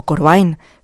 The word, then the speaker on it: Corbijn: que Anton Corbijn, o Corbijn, que es realmente la pronunciación correcta en holandés, pues que Anton Corbijn fue el mismo a rodar en las seis localizaciones originales de cada una de las historias.